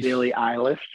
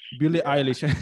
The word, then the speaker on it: Billie Eilish: We can also go into sports, but to stick with Beyonce, man, like, were you surprised that she lost to Billie Eilish? Am I saying it right? Like Eilish or Billie? – Billie Eilish. – Billie Eilish.